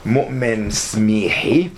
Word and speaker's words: Moumen Smihi: And our very special guest of honor, Moumen Smihi. I'm pronouncing it half Arabic, half French here.